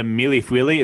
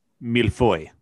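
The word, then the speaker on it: mille-feuille: So I do have to say that the milifuili is pronounced milfoy.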